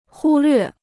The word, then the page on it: 忽略: hū llüè